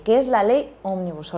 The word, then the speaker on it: Omnibus: y sobre todo por acompañarnos con el tema que vienes a hablarnos hoy, que es de la ley Omnibus, que ya me has confirmado que lo he pronunciado bien.